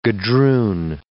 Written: gadroon

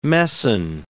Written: messan

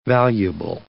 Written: valuable

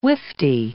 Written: wifty

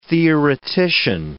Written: theoretician

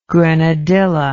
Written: grenadilla

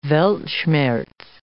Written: weltschmerz

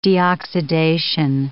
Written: deoxidation

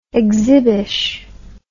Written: exhibish

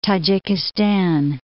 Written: Tajikistan

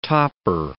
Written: topper

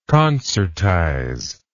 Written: concertizer

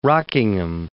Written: Rockingham

